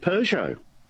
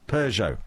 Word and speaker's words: Peugeot: How would you pronounce this particular car brand from France that actually produced cars at one point in Australia? What's the pronunciation? – I believe Peugeot. – Peugeot.